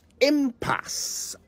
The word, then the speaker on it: impasse: Impasse. I-M-P-A-S-S-E.